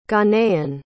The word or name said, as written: ghanaian